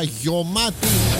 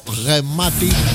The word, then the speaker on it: γεμάτη: Εδώ είμαστε, επιστρέψαμε και έχουμε μισή ώρα γιωμάτη. Δεν λέω ποτέ γεμάτη, γιατί κανονικά έπρεπε να προφέρω γεμάτη. Το γιώτα δεν υπάρχει γραμμένο στη λέξη.